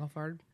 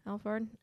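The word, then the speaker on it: Alphard: How do you say that? Alfred? – Mm-hmm. Alfred. – Alfred. Because there's an R.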